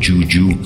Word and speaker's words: JJUU: Después continuará la psicología psicodelia bailable con algo nuevo de Juju, que se escribe JJUU, JJUU, todas en mayúsculas.